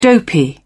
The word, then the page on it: dopey: British English